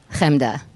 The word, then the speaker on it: Chemda: Like how do you say your own name? – Chemda. – So you go for it, but if I do that, I feel it's say it. Say my name.